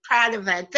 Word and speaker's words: Pradervand: Pradervand.